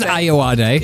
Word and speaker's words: Ayoade: I always wondered how you pronounce his surname. I didn't know it was Ayoade. – Yeah, well, you've learned something there, you don't. – Yeah. I'm guessing, if I'm honest. But yeah, I think that is how you say it.